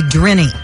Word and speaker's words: Drinny: I don't know if my dad used one on me, but my brother couldn't say my name, and he called me Drinny. D-R-I-N-N-Y.